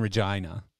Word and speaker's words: Regina: But so you grew up in Regina, which everyone outside of Canada just thinks it's a name that rhymes with fun, right? – Yep.